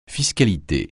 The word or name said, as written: fiscalité